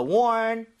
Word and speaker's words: warn: 근데 발음할 때 R이 살아있죠. – 예. – So, one 이러면 안 돼요. 앞으로 더 이상 warning 이러면 안 되고요. 혀를 뒤로 당긴 채 ning 하셔야 됩니다.